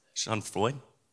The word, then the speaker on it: Schadenfreude: Schadenfreude? Did I pronounce that right that you remember? Okay, Martha's nodding her head, and she's, huh? Schadenfreude? Okay, great. It was one of those. It's, okay, sounds right.